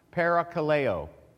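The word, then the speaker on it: parakaleo (παρακαλέω): Parakaleo.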